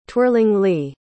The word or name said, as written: twirlingly